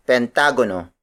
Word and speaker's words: pentagono: The next shape is pentagono. Pentagono. Pentagono. Pentagono is spelled as P-E-N-T-A-G-O-N-O.